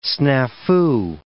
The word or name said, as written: snafu